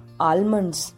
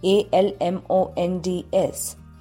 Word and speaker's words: almonds: बादाम is pronounced as आलमंड्स by most of the Indians. But actually, A L M O N D S. Here, L is silent. It should be pronounced as almonds.